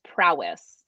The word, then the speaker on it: Prois: But when it came to upland hunting, there was pretty much, what is that, Prios? – I believe it's pronounced Prowess. – Prowess? – You think so about like, yeah, that sounds like it's spelled P-R-O-I-S, but correct. I think it's pronounced Prowess, which makes sense when that was pretty much all I knew about for upland.